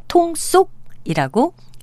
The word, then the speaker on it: 통속: 표기 형태는 한통 속의 통속과 같지만 발음은 달라서 통속이라고 두 번째 음절을 된소리로 발음합니다.